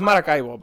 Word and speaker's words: Maracaibo: Well, it's Maracaibo. But I'm just kind of giving it the white American accent so you're, like... So, you understand.